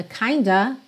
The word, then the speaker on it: kind of: A gente já viu aqui na série Aprenda Inglês com Música que essa expressão kind of muitas vezes é pronunciada kinda, kinda, e aí atualmente informalmente, claro, ela já é até grafada dessa maneira, ou seja, em letra de música, em mensagem de WhatsApp.